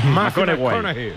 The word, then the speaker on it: McConaughey: Sí, cojones, me fichó, le gusta, hay que ir corrigiendo la pronunciación. – McConaughey.